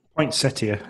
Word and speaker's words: Poinsettia: Poinsettia. So you are right with the pronunciation.